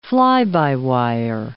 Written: fly-by-wire

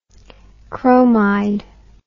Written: chromide

